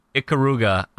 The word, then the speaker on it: Ikaruga: Ikaruga, I-K-A-R-U-G-A.